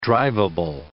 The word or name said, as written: driveable